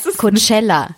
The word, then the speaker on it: Coachella: Also für die Leute, die es nicht wissen, wie spricht man das aus? Coachella. – Ja.